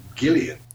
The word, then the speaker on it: Gillian: Actually, Brad, she pronounces her name Gillian.